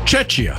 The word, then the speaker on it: Czechia: Game between South Korea and Czechia. – Czechia.